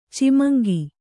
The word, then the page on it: ಚಿಮಂಗಿ: Kannada